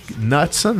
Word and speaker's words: Knutson: Porque o, o Eric aqui é Knutson, né? K-N-U-T-S-O-N, Knutson, né? O K é mudo e fica Knutson.